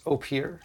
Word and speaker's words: Opihr: O-P-I-H-R is pronounced Eau Pure.